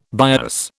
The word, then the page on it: bias: British English